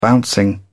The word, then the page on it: bouncing: British English